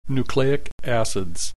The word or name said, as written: nucleic acids